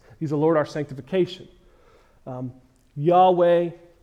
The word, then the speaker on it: Yahweh: I don't think God is offended by Jehovah, but I believe more accurately is pronounced Yahweh.